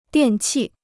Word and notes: 电器: diàn qì

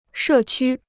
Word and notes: 社区: shè qū

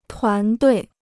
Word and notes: 团队: tuán duì

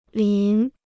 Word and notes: 淋: lín

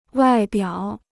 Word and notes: 外表: wài biǎo

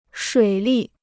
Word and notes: 水利: shuǐ lì

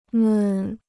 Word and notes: 们: men

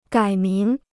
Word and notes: 改名: gǎi míng

